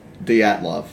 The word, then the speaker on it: Dyatlov: Is it I don't know how to pronounce it, the Dyatlov? – Dyatlov.